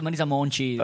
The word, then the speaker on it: Marisa Monte: Però vedi, Alessandro, insomma, se ricordava appunto, sì, non mi ha già messo diverse volte Marisa Monte. – Cioè, ma ti ha colpito perché Maiala che parla Marisa Monte? O dai, canto, c'è Marisa Monte. – Mi piace la pronuncia Marisa Monci.